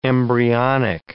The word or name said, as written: embryonic